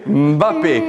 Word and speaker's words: Mbappé: guarda, non lo pronuncio bene, ma si chiama Mbappé. – Mbappé, Mbappé.